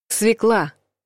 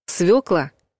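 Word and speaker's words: свёкла: Свекла — неправильный вариант. Свекла. Правильный вариант.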